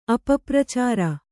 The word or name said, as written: ಅಪಪ್ರಚಾರ